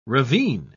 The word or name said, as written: ravine